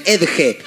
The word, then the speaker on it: Edge: ¿Así se pronuncia? ¿Está bien? Edge. – Ajá.